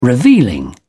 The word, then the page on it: revealing: British English